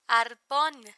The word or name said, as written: arpon